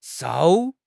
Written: shou4

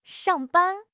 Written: shang4 ban1

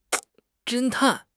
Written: 侦探

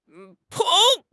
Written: 捧

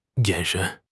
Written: 眼神

